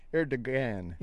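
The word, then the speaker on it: Erdoğan: Erdogan.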